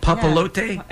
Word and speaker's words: Papalote: For more information about that, you can go to Trace Papalote. Do you think I'm saying that right, Lori? – It looks like it. – Papalote? – Yeah. Yes. – P-A-P-A-L-O-T-E.